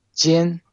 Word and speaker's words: Jian: L-I, Li, his family name, Jian, J-I-A-N, Jian, we call Jian, J-J for July, J-I-A-N. – Okay, so that's L-I and then a space and J-I-A-N for Nelly.